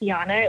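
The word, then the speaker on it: Janne: It's pronounced Yana. It looks like Jan, but if you're, my website is that.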